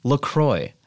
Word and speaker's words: LaCroix: Those assholes, if you go on their site, they say it's pronounced La Croix. – Sure. – Well, spell it C-R-O-Y then.